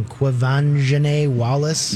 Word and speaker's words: Quvenzhané Wallis: And it had, I'm going to get her name wrong, Quvenzhané Wallis.